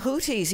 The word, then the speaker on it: Houthis: In one load, and you're going to go through the Gulf just past Yemen, where is it the Houthis? Is that the correct pronunciation? – The Houthis, yeah.